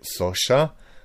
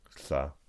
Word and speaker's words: Xhosa: So, like I said, the word, it's written Xhosa. So people would probably in English say Sosha, but the correct pronunciation is something like Ksa.